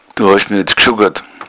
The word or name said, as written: schuggern